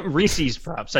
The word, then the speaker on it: Reese's Puffs: My favorite childhood cereal was Reese Puffs, you know, Reese's for breakfast, not Reese Puffs, whatever. – Reese's Puffs, I think that's how they say it.